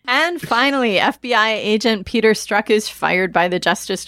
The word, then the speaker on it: Strzok: It is pronounced Strzok.